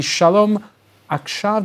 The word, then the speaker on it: Shalom Akshav: Thanks as well to Franco-Israeli peace activist Mark Lefebvre, spokesperson for the Shalom Akshav.